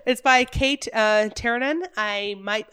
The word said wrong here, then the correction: Tiernan: Well, her last name is spelled T-I-E-R-N-A-N. – Tiernan. – Okay. Tiernan.